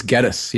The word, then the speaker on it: Geddes: Is that how they pronounce it? Geddes? – I believe it's Geddes, yeah.